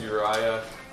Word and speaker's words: Uriah: My name's Uriah, rhymes with Kaya, that's why I'm here.